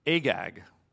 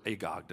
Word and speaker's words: Agag: And Haman's father is being called an Agagite means that he's a descendant of King Agag, or Agog, depending on how you say it.